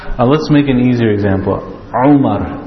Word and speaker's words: Umar: The ayin, it comes from the middle of the throat. Not like English. What do they write? They write Omar, like this, right? It's not Omar. Omar is Umar.